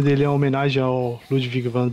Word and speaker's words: Ludwig: finalmente, pensei, pensei que você ia falar Ludwig, cara. Eu ia te xingar tanto se você falasse isso. Depois você pronunciou correto o nome dele em alemão. Ludwig.